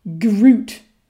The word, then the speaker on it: gruit: Well, for the elite biohacking beer drinkers out there, you'll want to start drinking Groot beer. And that's pronounced G R O O T or G R U I T.